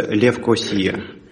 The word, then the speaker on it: Левкосия: Она так и читается, Никосия или Никозия? – А, вообще, на самом деле, Никосия это придумывали колонизировавшие в свое время англичане. Вообще город называется Левкосия.